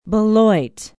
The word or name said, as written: beloit